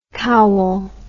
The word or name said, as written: Cowal